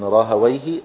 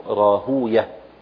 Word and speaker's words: راهويه: فيكون طريقة النحاة يعربونها فيقولون راهويه، وطريقة المحدثين ينطقونها كنطق الفرس لها يقولون راهوية،